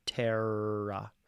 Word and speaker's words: terra: I would encourage you, if you can, I can't, to roll your R or in some other way close to it without saying the harsh R, terra.